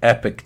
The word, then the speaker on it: Epoch: I'm going to put up a documentary that just came out from, I mean, it's spelled Epoch, but I guess people call it the Epic Times.